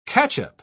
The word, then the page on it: catchup: British English